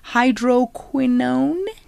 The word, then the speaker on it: hydroquinone: hydroquinone? Is that how you say it? – Yes.